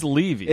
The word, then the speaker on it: Levy: It is Levy. – It is Levy. Here's the problem. Okay, it's spelled L-E-V-Y.